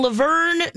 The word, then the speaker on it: Luverne: I did not know about this, but there are more nutcrackers than there are people in Luverne, Minnesota. And I hope that I'm saying that right. It's L-U-V-E-R-N-E.